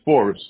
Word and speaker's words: Forrest: Oh, Edwante Forrest.